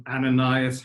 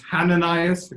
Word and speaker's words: Ananias: Now there was a certain disciple at Damascus named Ananias. Hananias, if you want to do the H, it can be pronounced either way.